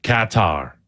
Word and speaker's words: Qatar: Canada playing, I'm going to say it in an English accent because I never know how to say this country's name. Qatar. – Qatar. – Yeah, Qatar. Mm-hmm. If you do it in an English accent like the announcers do.